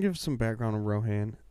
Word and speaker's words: Rohan: And Aldeburgh was the original capital of Rohan before Edoras and the Golden Hall of Meadowseld was built.